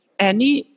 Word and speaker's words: Annie: Annie, it's A-N-N-I-E. And Massop, I pronounce it on Dutch, is M-A-S-S-O-P.